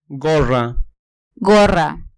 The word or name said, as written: gorra